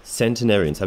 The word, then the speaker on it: centenarians: Da musst du irgendwie sagen, wie man das schreibt, weil bei Centenarians denke ich jetzt eher so an Heilige mit S-A-N-T und Cent, wie Century. Centenarians, habe ich es richtig ausgesprochen? – Ja, hast du ganz toll gemacht, aber...